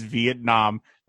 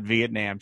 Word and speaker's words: Vietnam: and also, by the way, during the break, I looked up the correct, correct pronunciation of, of Vietnam, and it is Vietnam, not Vietnam. So I apologize, everybody.